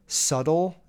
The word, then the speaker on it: subtle: Subtle? Is that how you say that word? So, like, I know it's spelled S-U-B-T-L-E, but you don't say subtle.